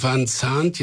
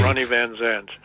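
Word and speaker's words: Van Zant: Van Zandt. Ich würde das Z stimmhaft, als stimmhaftes S aussprechen. – Okay. – Ronny van Zandt.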